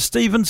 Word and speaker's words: Stephen: For example, my name is Stephen, spelled with a PH. No one calls me Stephen.